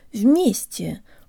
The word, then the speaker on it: вместе: guys, today you'll learn how to pronounce the word вместе, or together. In the U.S., some people say eggs, but others say eggs, and that's the sound we need for the stressed ye.